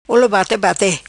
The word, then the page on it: Olo Bate Bate: patua